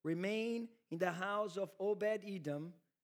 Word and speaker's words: Obed-Edom: Yeah, Obed-Edom.